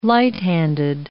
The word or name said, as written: light-handed